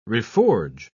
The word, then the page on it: reforge: British English